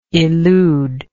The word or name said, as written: eluder